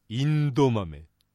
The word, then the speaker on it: エンドウ豆: エンドウ豆は発音はインド豆という言い方になります。